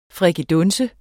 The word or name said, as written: frikadunse